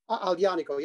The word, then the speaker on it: Aglianico: Like Aglianico, is that how you pronounce it? – Aglianico, yes. That's Aglianico.